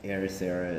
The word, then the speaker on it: Ericeira: No one will be able to spell it. Ericeira is how you would pronounce it.